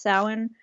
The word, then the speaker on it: Samhain: Yeah, so, yeah, that's, it's Celtic, hence why it's spelt Samhain, but it's pronounced Samhain.